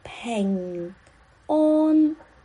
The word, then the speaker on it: 平安: 对，平安的“ 平” 是 “ping”，对，有鼻音。安的发音是 “on”，对，就是。 o n 好像 an 一样的 on 连起来是 say 乖 ping on 乖 ping on 对，还有对，还有一些人，他们把 an 呢发成 on。